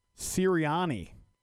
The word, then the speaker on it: Sirianni: Sirianni.